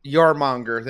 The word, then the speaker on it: Jormungandr: Always a bigger Jonamarg. I don't know how you pronounce the worm card. – Jormungandr. – There we go.